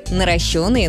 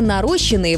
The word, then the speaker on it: наращенные: Запомните, мы говорим «наращенные ресницы», «наращенные волосы», «наращенные ногти». Везде один и тот же принцип. Ошибка «нарощенные» вообще выходит за рамки нормы. Такого варианта в русском языке нет.